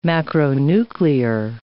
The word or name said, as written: macronuclear